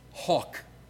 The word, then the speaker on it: hough: That's what it means to hawk a horse, H-O-U-G-H. It's an Old English spelling of something that we pronounce today, H-O-C-K, okay?